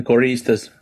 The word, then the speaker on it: Correístas: Do I say that right? Correístas? – Yeah, the Correístas.